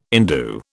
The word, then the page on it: indue: British English